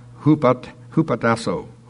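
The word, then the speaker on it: hupatasso (ὑποτάσσω): And the way they've got it pronounced here looks like hupatasso.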